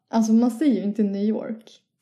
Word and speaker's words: New York: Ja, man ska säga New York. – Ja, utan att uttala R-et.